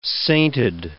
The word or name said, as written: sainted